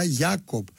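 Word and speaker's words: Jakov: Το όνομα του Τζάικοβ είναι Τζέικοβ και προφέρεται στα ελληνικά Γιάκοβ.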